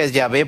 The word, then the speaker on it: Yahvé: Yahvé, porque en realidad se pronuncia Yahvé porque le agregaron algunas vocales.